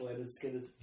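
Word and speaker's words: Lynyrd Skynyrd: It's pronounced Neolard Limoy, like a reference to Leonard Skinner. Remember that album? It's pronounced Leonard Skinner.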